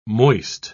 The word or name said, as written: moist